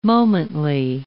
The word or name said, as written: momently